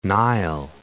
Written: Nile

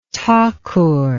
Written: Thakur

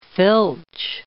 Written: filch

